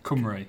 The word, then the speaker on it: Cymru: The Cumbrew. Did you say Cumbrew? How do you say it? Cymru. Cymru.